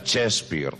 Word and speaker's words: Shakespeare: Digo, pues porque se escribe Chaspeare, pero se pronuncia Shakespeare.